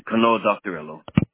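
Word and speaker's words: Kanoa-Doctorello: Like D-O-C-T-O-R. – D-O-C. – And then after doctor, it's E-L-L-O. – Okay. Kanoa doctor. How do you say that? Ello? – Rello. – Okay. – Yeah. Kanoa doctor Ello.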